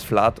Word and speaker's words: Vlad: Ist es Flat oder wir geben euch drei Auswahlmöglichkeiten. Ist es Flat der erste, Flat der zweite oder Flat der dritte? Flat übrigens schreibt man V-L-A-D.